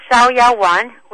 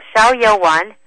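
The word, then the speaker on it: Xiaoyaowan: It's called Xiaoyawan, which is X-I-A-O, and then Y-A-O-W-A-N. It's called Xiaoyawan, or sometimes in some of the Chinese stores, they call it Free and Easy Wanderer.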